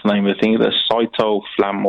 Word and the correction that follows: citalopram: or cyto, whatever it is. – Citalopram.